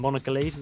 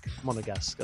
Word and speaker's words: Monegasque: He's, I don't know, I think Monegalese, is that how you say it? Something like that. – Monegasque.